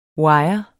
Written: WIRERNES

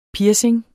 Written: piercing

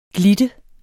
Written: glitte

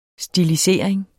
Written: stilisering

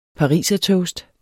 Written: parisertoast

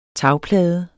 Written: tagplade

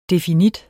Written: definit

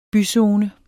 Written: byzone

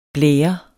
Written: blære

